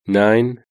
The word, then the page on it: nine: British English